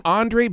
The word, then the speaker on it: Andry: how to pronounce Andre's name. Is it Andre? Andre? Because, I mean, that's a conversation starter right there. – I say Andre. – Andre, all right. My wife wins.